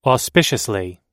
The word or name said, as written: auspiciously